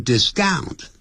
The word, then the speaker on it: discount: 多少钱啊 Clerk 就讲了 Since you are the first customer this morning 由于你今天早上呢是第一个来的客人 Customer 就是顾客 I'll offer you 我会提供你 A 20% discount 一个 20% 的折扣 Discount 不要念成 discount 重音在第一个音节 discount 也就是给你打八折打七折的话就是 I'll offer you a 30% discount 打六折就是 40% discount How about that?